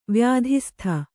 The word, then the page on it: ವ್ಯಾಧಿಸ್ಥ: Kannada